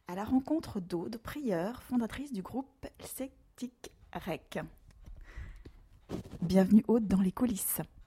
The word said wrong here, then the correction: Celtik'Raic: Alors je me permets juste de te corriger, on dit Celtic Crack, ça s'écrit Celtic Crack, mais en fait c'est du gaélique. Ça veut dire un moment fun celtique, donc voilà, ça se dit avec l'accent Celtic Crack.